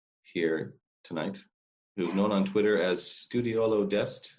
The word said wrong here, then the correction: Deste: Is that how you say it? – Deste. – Deste, sorry.